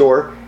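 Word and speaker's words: or (עור): spelled ayin, vav resh, which is pronounced or, he wrote in the margin the letter aleph, meaning aleph vav resh, meaning or, which is light.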